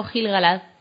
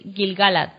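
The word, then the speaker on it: Gil-galad: Y además, y además Galad, acabado en, o sea, con, poniendo el acento en la última, que en realidad es Gilgalad, ¿no? Eh, sería así.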